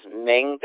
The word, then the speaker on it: Ming: My name is Ming, that's spelled Mike India November Golf. Again, that's Mike India November Golf. My name is Ming, I'm calling from Florida, USA.